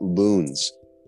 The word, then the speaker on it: Lons: It's spelled L-O-N-S. It's pronounced Loons, and it's at the Esmeralda Resort, just like you're saying.